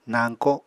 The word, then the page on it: nañko: Apatani